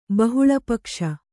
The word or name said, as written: ಬಹುಳಪಕ್ಷ